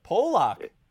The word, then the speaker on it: Pulock: Pronounce the last name for us once and for all. – Yeah. Well, no, I think I go by Pulock, but Pulock.